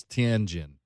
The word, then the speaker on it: Tianjin: That's Tianjin.